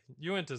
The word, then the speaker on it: Uinta: Uinta is how you say it.